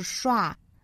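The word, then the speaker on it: schwa: 那很多同学听我说这个小刷音小刷音，这个刷到底是什么呢？其实大家可以百度一下，说 S-C-H-W-A, 其实就是那个弱读音节倒着写的那个小写的 E, 就是那个小刷音了，因为它的发音其实就是刷。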